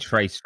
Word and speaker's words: tres: Trace very clearly rather yeah. than Tress, which might just have worked Without Tress.